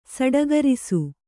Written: ಸಡಗರಿಸು

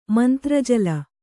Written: ಮಂತ್ರಜಲ

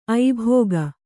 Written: ಐಭೋಗ

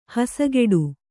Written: ಹಸಗೆಡು

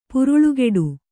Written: ಪುರುಳುಗೆಡು

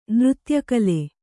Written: ನೃತ್ಯಕಲೆ

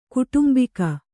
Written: ಕುಟುಂಬಿಗ

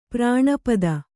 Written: ಪ್ರಾಣಪತಿ

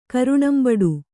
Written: ಕರುಣಂಬಡು

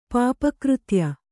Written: ಪಾಪಕೃತ್ಯ

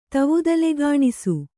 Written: ತವುದಲೆಗಾಣಿಸು

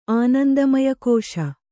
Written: ಆನಂದಮಯಕೋಶ

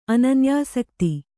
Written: ಅನನ್ಯಾಸಕ್ತಿ

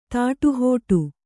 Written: ತಡೆಹಿಡಿ

